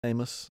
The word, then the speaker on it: Amos: So you have Isaiah, you have Isaiah, Jeremiah, Lamentations, Ezekiel, Daniel, Hosea, Joel, Amos. Is that, I say that right? – Amos. Oh, yeah, Amos.